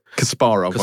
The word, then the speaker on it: Kasparov: And he plays Garry Kasparov. Is that how you pronounced it? – Kasparov, I believe, is how he pronounces it.